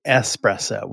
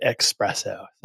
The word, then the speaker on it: espresso: You know, if coffee isn't your thing, the word is actually espresso with an S. There's no X in it.